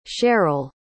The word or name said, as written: Sherryl